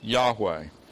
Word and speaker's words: Yahweh: Yahweh is four Hebrew consonants, and we're pretty sure that those four consonants were pronounced Yahweh.